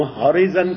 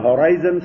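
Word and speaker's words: horizon: ہم ہوریزن کہتے تھے، پھر کہیں ریڈیو تقریروں میں ہورائزن سنا.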